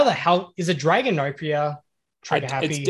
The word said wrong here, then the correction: Danganronpa: Danganronpa. – Okay. – Danganronpa.